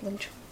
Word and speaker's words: Wing Chun: Did I say it right, Wing Chun? – Yeah.